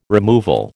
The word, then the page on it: removal: American English